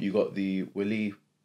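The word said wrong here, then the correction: Wli: waterfall, or Lee. Why did I say Willy? You got the Lee waterfall.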